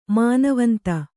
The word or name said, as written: ಮಾನವಂತೆ